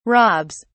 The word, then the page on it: robs: British English